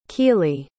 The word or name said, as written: Kealy